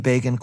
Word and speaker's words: Begin: Time magazine infamously informed its readership that the name Begin, quote-unquote, rhymes with Fagin, referencing the anti-Semitic stereotype of Dickens' Oliver Twist.